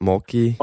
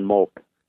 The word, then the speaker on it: Moltke: Would you mind telling our listeners a little bit about, and I'm probably not going to pronounce his name properly, but Moltke, is that how you say that? Count Moltke? – Oh, yes. James Helmut von Moltke.